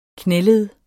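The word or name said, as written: KNÆLED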